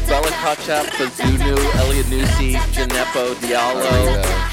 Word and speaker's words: Kotchap: Ketchup? – It looks like ketchup. Kochap. – Kochap. – Like it's straight up. – Kochap. Spell it. – K-O-T-C-H-A-P.